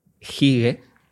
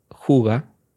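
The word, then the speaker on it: hygge: Porque estoy hablando de Hige, el Hige, que me diréis, ¿qué está diciendo este hombre? Ha perdido la cabeza. El Hige, que en realidad se pronuncia juga,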